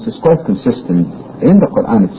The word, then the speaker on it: Quran: I have to brush up on my pronunciation myself of Quran, long A.